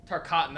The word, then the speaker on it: Tarkatan: Tartan? Isn't that his race in Mortal Kombat? Is he making that? Tarkatan, that was right. I knew it was close. I was missing a syllable in there.